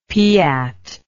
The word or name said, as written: piat